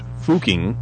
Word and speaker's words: Fucking: And the town is, of course, Fuching, Austria, and it's pronounced, or it's spelled in the English way, F-U-C-K-I-N-G. But it's actually pronounced Fuching.